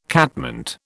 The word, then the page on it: catmint: British English